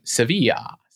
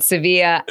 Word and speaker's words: Sevilla: I think the Spanish call it Sevilla. Sevilla, Jill. Do I have that better? Is that better than pistachio? – It is. It is. They do pronounce it Sevilla.